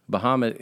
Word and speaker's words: Bahamut: First of all, I didn't realize this until a couple days ago, but Bahamut is how you pronounce it in Arabic, is from mythology.